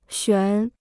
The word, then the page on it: 悬: xuán